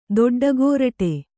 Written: ದೊಡ್ಡಗೋರಟೆ

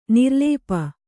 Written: ನಿರ್ಲೇಪ

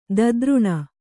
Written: ಧಾತುರೇಣು

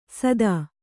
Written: ಸದಾ

